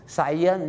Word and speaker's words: science: ที่จริงแล้วนะครับวิทยาศาสตร์นะครับไม่ยากครับก็คือออกเสียงว่าไซแล้วก็อย่างนี้ครับ Science นั่นเองครับแต่ไซเยนเนี่ยมันคล้ายๆเยนเนี่ยนะฮะ Science นั่นเอง